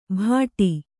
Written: ಬಡಿ

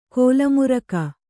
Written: ಕಾಲಮುರಿಗೆ

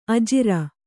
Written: ಅಜುರ

